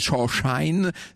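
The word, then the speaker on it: Chauchoin: Faut dire que Shawshine, c'est pas très joli, puis c'est surtout très difficile à prononcer pour les Américains, qui dit Shawshine, c'est-à-dire le cirage, vous savez, le cirage à chaussures.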